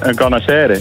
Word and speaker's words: carnachère: Een carnachere? – Een carnachère. – Een carnachère, ja.